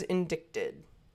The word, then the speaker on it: indicted: But the C is silent. Why? – Indicted? – Because there's a C in indicted.